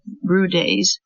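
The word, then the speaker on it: Bryde's: But NHMAD now has 5 Emirati assistant curators studying everything from the spiders of the Arabian Peninsula to a 50-foot-long bride's pronounced Brudaise whale skeleton that turned up in a mangrove forest 15 minutes from the museum.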